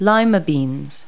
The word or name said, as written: lima bean